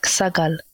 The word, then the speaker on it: Sagal: Yeah, it's like short A sounds. It's like Sagal, short sounds.